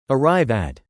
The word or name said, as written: arrive at